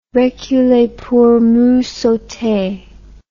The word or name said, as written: reculer pour mieux sauter